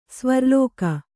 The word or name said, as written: ಸ್ವರ್ಲೋಕ